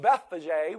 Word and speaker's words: Bethphage: And so in Luke 19, it says this: as he approached Bethphage, I've heard this pronounced so many times, but I'll pronounce it this way, as he approached Bethphage,